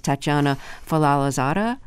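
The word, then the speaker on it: Fazlalizadeh: And her name, if I'm pronouncing it correctly, is Tatiana Falalazada. – Yep, that's it.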